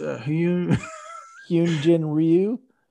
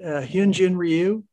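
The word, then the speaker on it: Hyun-jin Ryu: It's Hyunjin Ryu. – That's right. Why don't we just let you do it? Hyunjin Ryu is another solid starter.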